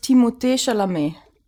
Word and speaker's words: Timothée Chalamet: Also, wenn man wirklich Französisch das ausspricht, ist das Timothée Chalamet. – Das reicht sich das ja. Das ist ja viel geiler. Timothée Chalamet.